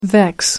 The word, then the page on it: vex: American English